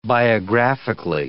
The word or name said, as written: biographically